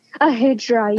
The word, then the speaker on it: Hijra: And the character of Lovely, who is a trans woman, and tell me if I'm pronouncing this correctly, is it Hijra? – Ahijra, yes.